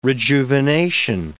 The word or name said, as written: rejuvenation